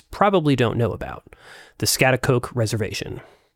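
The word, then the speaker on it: Schaghticoke: Skaticook. – The correct pronunciation is Skaticook, and I'm sorry in advance because you're going to have to hear me mispronounce it in my field tape quite a bit.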